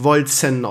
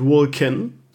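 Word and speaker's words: Wolcen: Aber ich hatte jetzt, also keine Ahnung, ich habe jetzt mehrere Streams Wolzen gespielt, übrigens, ich habe gelernt, Die Entwickler haben gesagt, es wird wirklich Volzen ausgesprochen und nicht Vulcan. Also Azurias hatte Unrecht, das möchte ich an dieser Stelle nochmal sagen. Azurias hatte Unrecht. Volzen wird es ausgesprochen.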